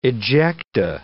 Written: ejecta

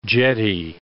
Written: jetty

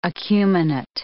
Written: acuminate

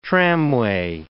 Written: tramway

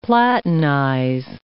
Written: platinize

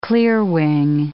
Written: clearwing

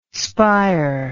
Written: spier

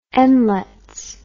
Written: nlets

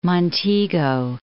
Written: Montego Bay

